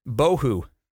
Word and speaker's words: bohu: And void is pronounced bohu. Okay, so you hear it? It was tohu and bohu.